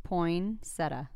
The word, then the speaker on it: poinsettia: How do you pronounce it? I think it's poinsettia. – Poinsettia. – Yeah.